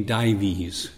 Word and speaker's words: Dives: And I think that they give the rich man the name Dives. It's spelled like dives, but Dives.